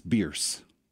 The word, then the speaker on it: Bearss: It's like B-E-A-R-S-S. That's a road in Tampa. – Yeah, Bears. – It's Bierce. Like Pierce with a B.